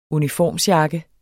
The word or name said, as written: uniformsjakke